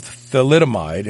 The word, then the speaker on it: thalidomide: Dean Paulson, the women's drug that causes thalidomide, it's actually a TH. I'm almost positive it's not P, it's TH.